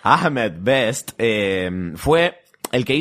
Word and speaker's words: Ahmed: El actor Ahmed, Ahmed Best Ahmed Best, ¿cómo se dice? Ahmed. Ahmed, Ahmed, Best.